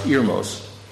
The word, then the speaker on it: Heirmos: It says, Hermos, H-E-I-R-M-O-S. It's usually pronounced, it's a Greek word, usually pronounced Irmos.